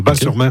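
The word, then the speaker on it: Batz-sur-Mer: Moi, on prononce Bas, le T ne se prononce pas. Bas-sur-Mer.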